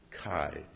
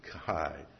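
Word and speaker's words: chai (חי): Now the word life, the Hebrew word for life is chai. You spell it C-H-A-Y, and you pronounce it chai. Or I put it, for me, I put K-H-I-G-H, if you think chai, chai.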